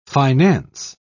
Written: finance

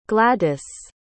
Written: gladis

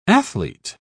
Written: athlete

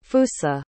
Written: fusa

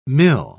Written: mil

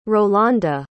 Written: rolanda

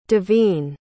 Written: daveen

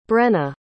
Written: brenna

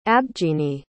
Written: abjini

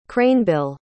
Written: cranebill

